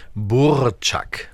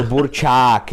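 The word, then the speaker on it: burčák: reden derzeit alle wieder bei euch über diesen Burczak? – Also äh, völlig falsch äh, ausgesprochen, da gibt es noch oh. ein langes A, also ah. – Burczak.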